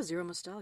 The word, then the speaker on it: Zero Mostel: Yeah, Zero Mostel.